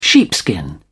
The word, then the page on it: sheepskin: British English